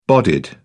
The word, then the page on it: bodied: British English